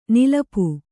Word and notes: ನಿಲಿಪು: nilipu

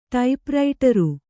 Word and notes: ಟೈಪ್‍ರೈಟರು: ṭaip raiṭaru

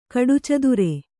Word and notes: ಕಡುಚದುರ: kaḍucadura

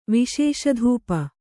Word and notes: ವಿಶೇಷಧೂಪ: Kannada

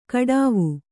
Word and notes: ಕಾಡವೆ: kannada

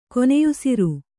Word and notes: ಕೊನೆಯುಸಿರು: koneyusir

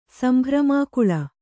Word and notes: ಸಂಭ್ರಮಾಕುಳೆ: kannada

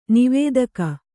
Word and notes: ನಿವೇದಕ: nivēdaka

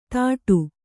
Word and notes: ಥಾಟು: kannada

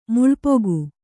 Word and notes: ಮೆಲ್ಪೊಗೆ: melpoge

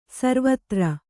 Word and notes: ಸರ್ವತ್ರ: sarvatra

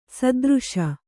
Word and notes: ಸೈತಿರಿಸು: saitirisu

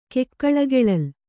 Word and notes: ಕೆಕ್ಕಳಗೆಳಲ್: kannada

